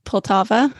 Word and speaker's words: Poltava: He was born in Poltava.